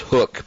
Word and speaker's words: HUK: 40% off HUK clothing, which is hook, pronounced hook.